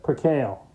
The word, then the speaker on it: Percale: Is Percale? Is that how you pronounce it? Percale. Thank you.